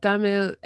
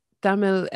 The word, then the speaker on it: Tamil: And am I saying Tamil correctly? – Yes, actually, you're pronouncing it quite right. Like, in the English way, we can say Tamil, but Tamil is actually the more authentic way.